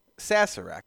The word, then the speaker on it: Sazerac: Sazerac. – Maybe I have to have S-A-Z. – A couple. – S-A-Z-E-R-A-C.